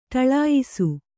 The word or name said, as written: ಢಾಳಯಿಸು